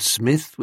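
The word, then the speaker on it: Psmith: and stories about Smith with a silent P,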